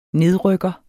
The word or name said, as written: nedrykker